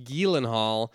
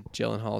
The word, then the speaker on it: Gyllenhaal: He said it's actually pronounced Gyllenhaal from, I think it's Scandinavian, but they've just adapted it in the U.S. to Gyllenhaal is fine.